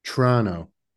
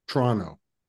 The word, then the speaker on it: Toronto: I guess you're not supposed to say the T. The second T. – Toronto. – And I've heard some people who are in the know say that it's supposed to be pronounced as a two-syllable word. Toronto.